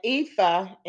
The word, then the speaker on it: ephah: And an ephah, and this is how it's pronounced.